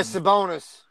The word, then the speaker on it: Sabonis: Yeah, Sabonis.